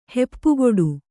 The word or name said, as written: ಹೆಪ್ಪುಗೊಡು